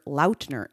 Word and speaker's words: Lautner: News on February 3rd to promote his latest partnership with Courtyard by Marriott, Taylor introduced himself by his full name, revealing the first syllable of his last name actually rhymes with out and not lot. So that would make him Mr. Taylor Lautner instead of Lautner.